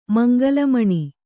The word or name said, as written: ಮಂಗಲಮಣಿ